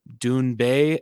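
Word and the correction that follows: Doona Bae: as Duna Bay. Oh, I guess that's how you say her name. My bad. They told me wrong.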